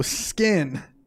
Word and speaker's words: SKYN: No, skin.